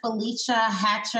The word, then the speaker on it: Felecia Hatcher: And today we have with us Felecia Hatcher.